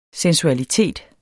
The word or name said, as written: sensualitet